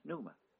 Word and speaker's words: pneuma: The word for spirit in the Greek language is pneuma, pronounced pneuma. The word for air in Greek is also pneuma.